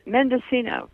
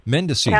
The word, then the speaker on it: Mendocino: Between 1989 and 1993, worked as an agricultural technologist for, I believe it's, uh, how do you say it? Is it Medoc-? – Mende- Mendocino. – Mendocino.